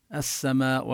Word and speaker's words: السماء: من السماء كذلك، هنا الألف واللام بلفظ السماء تدغم اللام بالسين، السماء ولا نلفظها، من السماء لا تلفظ اللام.